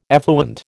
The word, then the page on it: effluent: American English